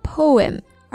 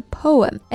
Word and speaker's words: poem: Yeah，它的英音读作 poem。而美音呢就是 poem。